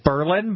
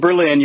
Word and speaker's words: Berlin: If you're in Ohio and you are in the town Berlin, you are in what town? – I'm going to guess Berlin because that's how they pronounce it in New Hampshire. – Yeah. You're not in Berlin. You're in Berlin.